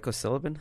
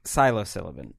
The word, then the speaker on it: psilocybin: It took a broad view about how psilocybin? How do you say that? – I know what you're talking about, but I couldn't tell you how to pronounce it. – Psilocybin.